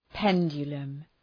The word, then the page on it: pendulum: ‘pendʒələm